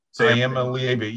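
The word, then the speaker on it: Iamaleava: So, and I believe it's Iama Lieva. I'm not sure if that's it. – Yeah. Iama Lieva. You have to pronounce every one of the vowels. They don't, there's nothing silent.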